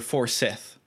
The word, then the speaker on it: Forsyth: Is it Forsyth or Forsyth? – I pronounce it Forsyth, as indeed do most people. But then I called the plant Forsythia, not Forsythia. So anyway, most people will seem to say Forsyth.